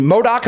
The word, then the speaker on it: MODOK: He's also a voice in MODOK.